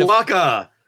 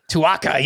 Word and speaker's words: Tuaca: And Tuaca. – Tuaca, yes.